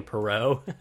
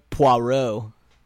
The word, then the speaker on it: Poirot: we're probably mispronouncing his name. I keep calling him Poirot because of Ross Poirot. – Yeah, it's Poirot. – Poirot. Who knows? We're probably still saying it wrong, but Poirot. – That's how the pronunciation guide says to say it.